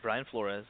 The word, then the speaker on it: Brian Flores: Yeah, Brian Flores.